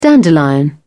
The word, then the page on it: dandelion: British English